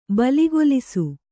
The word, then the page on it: ಬಲಿಗೊಲಿಸು: baligolisu